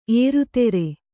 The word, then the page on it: ಏರುತೆರೆ: ērutere